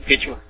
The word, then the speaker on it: Quechua: but I understand he was a Quechua Mayan. – Yeah, Quechua.